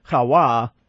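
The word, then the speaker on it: Hawa (חוה): It's in Hebrew, Hawa, or something along those lines, rather than the way we pronounce it, isn't it? – Yeah, the Hebrew Hawa became Ewa in Greek, and then we took it over from the Greek.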